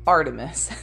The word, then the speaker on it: Art3mis: the blog of a female Gunter named Artemis, pronounced Artemis. So in the book, it's spelled A-R-T-3-M-I-S.